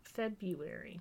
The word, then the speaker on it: February: February. – February. – Yeah. I don't know. It's a hard month because of the R, but it doesn't sound like an R.